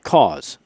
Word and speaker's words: CAWS: I do want to ask you about CAWS, C-A-W-S, right? Am I pronouncing that correct? – Yes, you are.